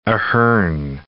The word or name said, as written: Ahern